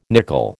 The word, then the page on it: nickel: American English